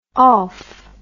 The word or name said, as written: olf